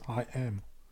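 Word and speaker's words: Haim: Wo wir gerade bei den Gastauftritten sind, wir haben noch zwei weitere Gastauftritte auf dem Album oder Heim. Features. Einmal Heim bei Nobody No Crime und dann nochmal Bon Iver bei Evermore. – Bitte, wirklich? Kollege, Aussprache ist I Am.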